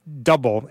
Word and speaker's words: Double: So, from Rhapsody, an artist I can't tell you anything about, because it's not double anymore, or Double, as it's pronounced, it's Double Side.